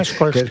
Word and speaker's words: Skolsky: No, señor, no es SH, es SKOLSKY.